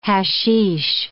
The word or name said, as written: hashish